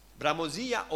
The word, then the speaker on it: bramosia: Dimmi un po' come si pronuncia, maestra. Bramosia o bramosia? Bramosia. Ok.